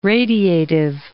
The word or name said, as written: radiative